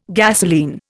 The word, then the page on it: gasolene: Indian